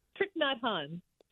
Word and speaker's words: Thich Nhat Hanh: Thich Nhat Hanh.